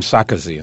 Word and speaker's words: Sarkozy: No, I met with, he pronounced his name Sarkozy. – Sarkozy.